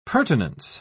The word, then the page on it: purtenance: British English